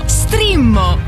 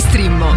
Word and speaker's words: Streammo: No, Strimmo! Entra adesso nel mondo di Strimmo. Vai su strimmo.it, si scrive Streammo o scarica l'app.